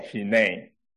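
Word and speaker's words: hine (הנה): 那如果发音的话，它叫做 hine。 Okay。那再来去看看说 hine 这个字在旧约里面出现过几次，大家要不要猜一下？ here I am 我在这里出现十次， hine 出现了几次？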